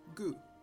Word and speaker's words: god: The word for good in Norwegian is spelt G-O-D, but is pronounced gu. The word for red is spelt R-E-D, but is pronounced R.